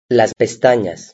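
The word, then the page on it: las pestanas: Spanish (Spain)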